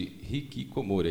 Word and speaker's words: Hikikomori: E essa situação fez aparecer uma tribo de pessoas que são chamadas de Hikikomori, é isso mesmo? – É isso mesmo. – Ah, tá. Falei certo, né? – Falou certinho.